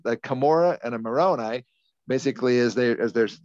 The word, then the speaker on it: Moroni: It's spelled M O R O N I. – It's oh, Moroni, Mer- Moroni. – Yeah. – And we would call it Moroni. – Moroni. Yep.